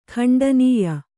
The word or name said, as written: ಖಂಡನೀಯ